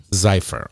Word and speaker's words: Zephyr: Zephyr. Did I say that right? – Zephyr.